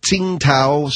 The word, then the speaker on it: Tsingtao: Then there's Tsingtao. Tsingtao, some people pronounce it.